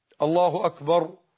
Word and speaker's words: الله أكبر: الله أكبر، هكذا بارك الله فيك، لا تطول فيه، الله أكبر، أي نعم. وبعض الناس ينطقون الكاف جيم، يقول لك الله أكبر، ده خطأ، أمر الصح إيه؟ الله أكبر، أي نعم.